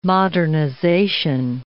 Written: modernization

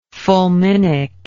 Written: fulminic

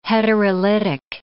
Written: heterolytic

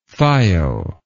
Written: thio

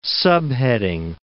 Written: subheading

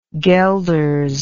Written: guelders

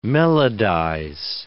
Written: melodize

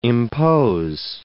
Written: impose

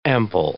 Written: ample

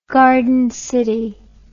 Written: garden city

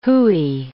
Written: hooey

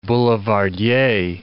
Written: boulevardier

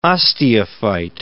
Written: osteophyte

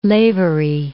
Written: Lavery

